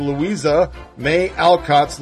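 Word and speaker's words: Louisa May Alcott: A whole new generation is about to be introduced to Louisa Mae Alcott's Little Women.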